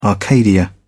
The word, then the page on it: arcadia: British English